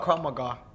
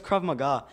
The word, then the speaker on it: Krav Maga: You're talking about Krav Maga. – Yeah, I know. It can be serious. – I don't think I said that right. – No, it's Krav Maga.